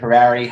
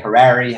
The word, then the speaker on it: Harary: There's a big debate in my family. Is it Harary? Is it Harary? Half of us say Harary, half of us say Harary. My grandpa says Harary, which doesn't help anyone.